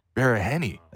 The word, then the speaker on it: Baraheni: Okay, well, the last name you can like enunciate it if you want to. Barahenny. – Barahenny.